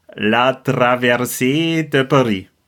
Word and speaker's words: La Traversée de Paris: La Traversée de Paris.